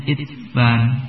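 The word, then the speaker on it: Itsban (عتبان): Wa Utsman qala rahimahullah, ya, wa Utsman bi kasril muhmalah, ya, sahabat Utsman, siapakah beliau, cara bacanya bi kasril muhmalah, dengan mengkasrahkan ain tanpa titik, muhmalah tanpa titik, ba'daha musannatun, setelahnya bertitik 2 fawqiyah di atas, yaitu ta, tsumma muwahhadatun, kemudian titik 1 yaitu ba. Jadi bacanya Itsban.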